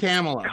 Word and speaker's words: Kamala: It's Kamala. – Kamala. Not Kamala. Kamala. – Kamala. – Kamala. – Potato, potato. Yeah, no, okay, Kamala Harris. No, he's right. – Kamala as in the Kamala Harris.